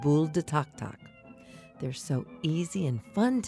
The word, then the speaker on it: tac-tac: What is Tok Tok? Tok Tok, which is spelled T-A-C-T-A-C, is Cajun for popcorn.